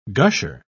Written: gusher

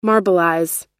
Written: marbleize

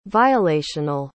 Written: violational

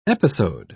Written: episode

